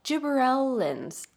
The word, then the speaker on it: gibberellins: but gibberellins. It's G-I-B-B-E-R-E-L-L-I-N-S, gibberellins, which I think is a great word.